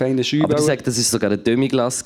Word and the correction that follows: Demi-Glace: kein Schü. – Demi-Glace. – Ja. ja. vielleicht habe ich es falsch gesagt.